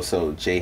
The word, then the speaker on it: Jhené: And so Jhené Hennessy is cool. – And Jhené Hennessy is, she's, well, how my name is spelled, J-H-E-N-E.